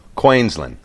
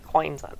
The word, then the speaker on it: Queensland: Pronunciation guide for anyone taking a punt at the Aussie accent, Queensland. – Queensland. You did a good job with that.